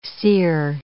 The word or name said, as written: cere